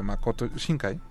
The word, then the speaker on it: Makoto Shinkai: Aprovechando que hablamos de la nueva película de Makoto Shinkai.